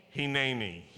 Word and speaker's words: Hineni: Now how do you say here I am in Hebrew? Hineni.